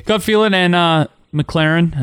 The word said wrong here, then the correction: McLaurin: I think that's how you say his name. Or yeah, McLaurin? Yeah, McLaurin. McLaren. – I think it's McLaurin.